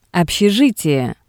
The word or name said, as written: obshejitie